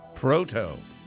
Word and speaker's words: Proto: Proto. I love that. – Well, it rhymes with Toto.